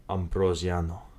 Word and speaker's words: Ambrosiano: Ambrosiano.